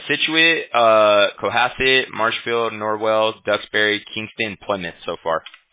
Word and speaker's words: Kingston: You said Kingston? Did I say that right? – Kingston.